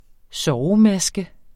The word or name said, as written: sovemaske